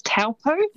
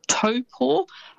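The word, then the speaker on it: Taupō: But famous spots including Taupo, so quite often you'll hear that said as Taupo. – Yes. – It's almost how it's spelt, but that Taupo is actually pronounced Taupo.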